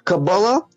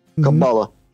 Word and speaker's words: Каббала: И все, что я могу добавить к сказанному тогда, ранее, я могу сказать, что Каббала, или иногда в оккультно-эзотерической практике делается ударение на другом слоге, Каббала, это особое знание, вмещающее в себя некоторую часть гораздо более великого знания.